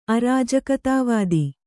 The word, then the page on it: ಅರಾಜಕತಾವಾದಿ: arājakatavādi